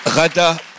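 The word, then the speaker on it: Ghada: Rada, prononciation à l'égyptienne ou Gada Hammer,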